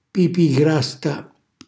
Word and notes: pipi i grasta: calabrese

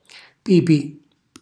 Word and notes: pipi: calabrese

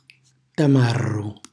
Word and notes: tamarru: Calabrese

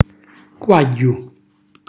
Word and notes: quagghju: calabrese

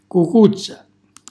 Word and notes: cucuzza: calabrese

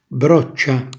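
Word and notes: broccia: Calabrian

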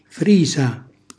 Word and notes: frisa: calabrese

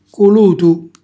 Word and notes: culutu: calabrese

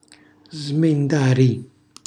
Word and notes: smendari: Calabrese

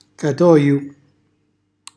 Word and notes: catoju: calabrese

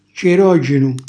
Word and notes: cirogenu: calabrese

